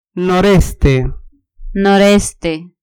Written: noreste

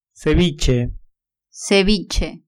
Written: ceviche

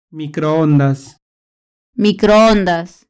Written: microondas

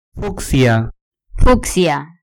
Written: fucsia

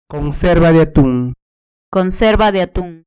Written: conserva de atun

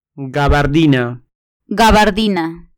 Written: gabardina

